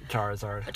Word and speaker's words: Charizard: Well, I finally, it took forever, and it was only recently that I got my, is it Charizard? – Yep, Charizard.